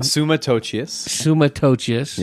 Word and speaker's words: Summa Totius: How do you say that again? – Summa Totius. Yep.